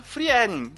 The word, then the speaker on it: Frieren: o Freedom. – É, ou, que nem eu pronunciei errado, Frieren.